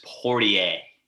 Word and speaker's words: Poirier: It's French. It's Poirier. Dustin Poirier.